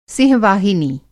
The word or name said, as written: सिंह-वाहिनी